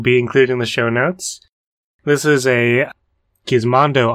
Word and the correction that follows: Gizmodo: article. – Gizmodo. – Huh. – Do you pronounce it Gizmodo? Is that what you said? – No, Mondo. I thought there was an N there. – There's no N.